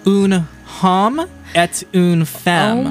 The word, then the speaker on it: Un homme et une femme: Un homme et une femme.